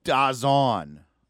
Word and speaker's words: DAZN: It's Dazen, right? Is that how you pronounce it? Dazen? – I thought it was Dazon, or like it's Dazon. DAZN. It's supposed to be like DAZN.